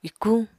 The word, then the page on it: ikuñ: apatani